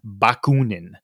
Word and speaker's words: Bakunin: From what I understand, and I could even mispronounce this right now, it's actually pronounced Bakunin is actually how you say it, because it's actually Russian, if anybody was actually kind of curious about that.